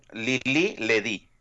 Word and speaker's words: Lili Ledy: What is the proper pronunciation of Lili Ledy? – It would say Lili Ledy.